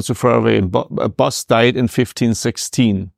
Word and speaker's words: Bosch: the time was difficult for the church, not actually at the time when Bosch, or Boss, Boss is the correct pronunciation right. in Netherlandish language.